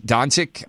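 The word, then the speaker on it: Dončić: Luka Doncic, Doncic I think is how you pronounce Doncic. his name. – Doncic. Yeah, Doncic. Uh, Jaren Jackson, uh, Marvin Bagley, Mohamed Bamba, Trae Young, uh, Michael Porter uh, Wendell Carter Jr., Collin Sexton, um, uh, Mikal Bridges.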